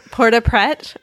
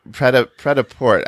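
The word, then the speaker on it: prêt-à-porter: Port-a-Pret, I think. – Pret-a-Port, I think it's the other way around. – Pret-a-Port, right? – It's ready to wear in French.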